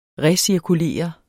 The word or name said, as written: recirkulere